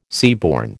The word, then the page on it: seaborne: American English